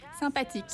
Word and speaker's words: Sympathique: Sympathique.